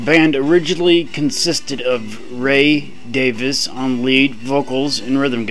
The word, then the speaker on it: Davies: For the longest time, I pronounced the last name as Davies, as most American fans probably have, but in their native England, it is Davis.